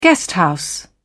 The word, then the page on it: guesthouse: British English